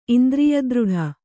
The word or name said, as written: ಇಂದ್ರಿಯದೃಢ